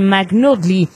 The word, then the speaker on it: McNulty: McNulty. Oh, si se pronuncia así. McNulty. Sí, McNulty. Ahí está bien.